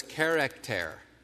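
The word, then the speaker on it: character (χαρακτήρ): If you know your Greek letters from college, if you remember them, the way to pronounce that is character. Character.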